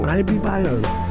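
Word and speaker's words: Ibibio: For 3 years, they have merged samba drum ensembles to Afrobeat surrealists, and this year have Abibio Sound Machine. Oh, we say that wrong. Abibio. – Abibio. – Abibio. – Abibio Sound Machine.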